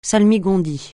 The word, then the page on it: salmigondis: francais